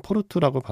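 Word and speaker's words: Porto: 포르투인데 국내 개봉 제목은 포르토라고 소개가 됐어요. 포르토로 검색을 하시면 되는데 예, 원래는 포르투라고 발음하는 게 맞겠죠.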